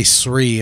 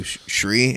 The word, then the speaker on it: Sri: I wanted to ask, I should ask earlier, but I hear some people say your name is Sri because it's just S-R-I. I say Sri. Am I saying it wrong or are they saying it wrong?